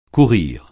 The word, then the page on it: courir: francais